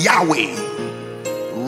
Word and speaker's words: Yahweh: He said, "These four letters, which we now pronounce as Yahweh, represents the entirety of my being."